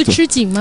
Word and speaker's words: 吃紧: 所以新党在可以说是哈，在财力也是那个时候也是最激紧，最那个这怎么念？ – 最吃紧吗？ – 吃紧，对，叫。